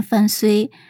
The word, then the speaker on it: 范雎: 但有些学者对一些古文进行了一些考究，就认为呢，这个雎字儿它应该念睢，所以呢，他名字应该念范睢。呃，但是呢，绝大多数人都是念居吧，所以我们在这里还是把它念作范雎好了哈。那这个范雎呢，他在战国的时期是魏国人，他的家庭成分对现在来讲的话，应该就是属于那种贫下中农哈。